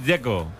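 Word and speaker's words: Džeko: Porque de Z, ¿cómo se pronuncia? – Dzeko. Bruno dice que es Dzeko. – Como tirando a J, Dzeko o algo así.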